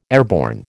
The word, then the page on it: airborne: American English